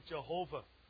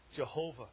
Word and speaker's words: Jehovah: Some people say Yahweh, but I don't. I say Jehovah. That's the right pronunciation of it, by the way. Jehovah.